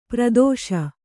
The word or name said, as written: ಪರದೇಶಿ